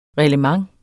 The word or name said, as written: reglement